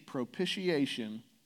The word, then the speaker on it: propitiation: propitiation for the sins of the people. You say, whoa. Propitiation. Propitiation. That's five syllables.